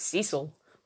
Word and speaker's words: Cecil: Um, there's a new like documentary series on Netflix about Elisa Lam and the Cecil Hotel. So that started, or, or, or as Americans pronounce it, Cecil, which I think's odd.